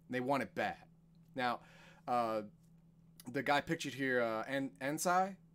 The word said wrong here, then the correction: Anzai: is that how you pronounce it? – I don't see his name. Oh, Anzai.